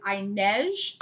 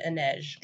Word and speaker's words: Inej: Next one I can think of is Inej. – I pronounced it Inej.